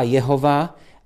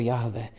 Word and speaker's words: Jahve: Když si ten tetragram JHWH propojíte s tím Adonai, tak vzniká Jehova, ale je téměř stoprocentně jisté, že takhle to jméno vyslováno nebylo. Je velmi pravděpodobné, že bylo vyslováno jako Jahve.